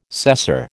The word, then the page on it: cesser: American English